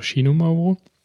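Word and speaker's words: Xinomavro: X, mit X schreibt man das. Xinomafro mit V, das spricht man, wenn ich das richtig kapiert habe, als Xinomauro. Vielleicht auch Xinomauro. Naja, dazu gleich ein bisschen mehr, wenn wir trinken.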